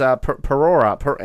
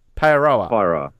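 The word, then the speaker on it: Paeroa: It's Parora. How do you say it? – Pairoa.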